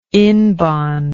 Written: inbond

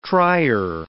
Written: trier

